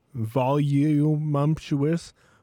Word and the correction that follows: voluptuous: Voluptuous. It's voluptuous. – There's no M.